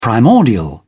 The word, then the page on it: primordial: British English